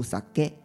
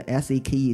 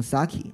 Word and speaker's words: sake: 当然，大家要就是知道这个英文它读起来不读 sake，它应该读 sake，因为我之前犯过这个错误，后来我日本那个朋友给我纠正过来了，说你看到这个英文的时候要读 sake。所以说，你如果说今后去日本旅游干嘛的，你要点日本酒，不要看成那个 s a k e 读成 sake，那是不对的，人家听不懂。你要点日本酒要读 sake，哎，那日本人就懂了。